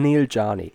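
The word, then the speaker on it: Neel Jani: With, I'm going to have to learn how to say this in the off-season, but Neil Yanni. – Or Yanni. – I think. – Yanni. Not too bad, yeah. – Yeah. – The Swiss saying Neil Yanni.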